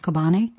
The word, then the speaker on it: Kobani: Speaking of the battle in the Middle East, ISIS has been battling in, I believe the town name would be pronounced Kobani.